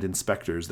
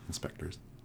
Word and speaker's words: InSpectres: And Inspectors, that's spelled INSPECTRES, like Ghost Spectre, right? Inspectors.